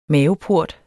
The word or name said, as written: maveport